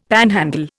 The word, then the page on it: panhandle: Indian